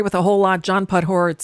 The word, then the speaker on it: Podhoretz: And New York Post John Podhoretz, is that how you say his name? – Yep. Yes.